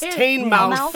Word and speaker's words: Teignmouth: The band could be asked to rule over Teignmouth. I don't know if I'm pronouncing that right. – Teignmouth? – It's Teignmouth.